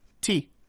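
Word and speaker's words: tea (茶): The exact same character, the Chinese character that he mentioned earlier, is pronounced tea.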